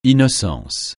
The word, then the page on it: innocence: francais